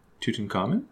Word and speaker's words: Tutankhamun: Tutankhamun? – Oh, I did it.